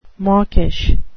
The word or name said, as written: mawkish